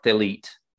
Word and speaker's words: Thelete: So, Sophia and Thelete, and that's spelled T-H-E-L-E-T-E, it looks like Thelete, but it's Thelete, they create the Anthropos.